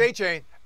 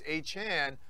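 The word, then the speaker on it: Achane: It's A-Chain. Andrew Siciliano and I were on a draft show, and Andrew asked him how to pronounce it, and he said A-Chain. I know Peter King in the comments today said it's A-Chan, but maybe he's changed it.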